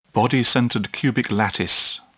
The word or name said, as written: body-centered cubic lattice